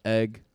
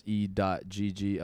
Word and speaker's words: E.GG: Yo, this is egg. That's E.GG, elevation for the greater good.